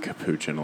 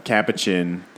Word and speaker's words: Capuchin: Another fun connection just with the word Capuchin is that people pronounce it Capuchin a lot in English, which I'm like, please don't. But Capuchin comes from the Italian word cappuccio, which means hood.